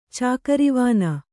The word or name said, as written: ಚಾಕರವಾನ